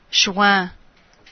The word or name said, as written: juin